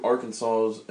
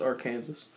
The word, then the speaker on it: Arkansas: As I'm driving through Arkansas and Oklahoma and Texas, do you know what was going through my head? You're pronouncing it wrong. It's Arkansas.